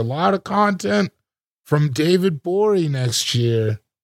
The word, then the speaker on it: Gborie: David Bory is here. Cool Guy Jokes 87 on Instagram. The G is silent on Twitter.